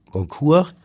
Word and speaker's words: Goncourt: Es geht um die Brüder Goncourt, Jules und Edmond. Habe ich es richtig ausgesprochen, Herr Moritz? – Ihr Französisch ist wie immer perfekt.